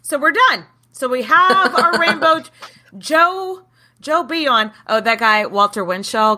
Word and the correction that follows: Bouillon: Is that how you say it? The Bion? – Bouillon. – I'm sorry. I've been saying it wrong.